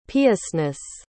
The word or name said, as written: piousness